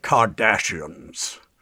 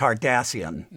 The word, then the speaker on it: Cardassian: And Patrick, he really wanted to say Kardashians as opposed to Cardassian.